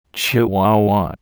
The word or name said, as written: Chihuahua